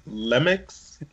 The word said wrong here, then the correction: Lemieux: We're all laughing here, but yeah, I'll give it to you. Mario Lemieux.